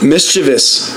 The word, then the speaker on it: mischievous: Mischievous. That's how you pronounce it. – M-I-C-H-I-V-I-O-U-S.